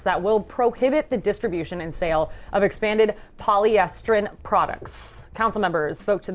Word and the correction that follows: polystyrene: What, did I say it wrong? – Polystyrene.